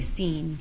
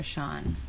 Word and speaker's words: Sean: They like to say seen for Sean.